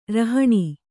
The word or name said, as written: ರೌಹಿಣ